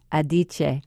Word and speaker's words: Adichie: It's Chimamanda Ngozi Adichie, A-D-I-C-H-I-E.